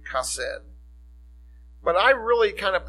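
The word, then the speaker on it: chesed: The Hebrew word is pronounced kesed.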